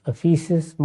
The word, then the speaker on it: افیسس: افسوس، افیسس، مختلف تلفظ ہیں اس کے.